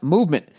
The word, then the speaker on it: MVMT: And clean, original designs, MVMT, pronounced movement, grew into one of the fastest growing watch brands, shipping to over 160 countries across the globe.